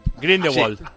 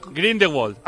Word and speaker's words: Grindelwald: Grindelwald. – ¿Cómo? – Grindelwald.